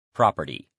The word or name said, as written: property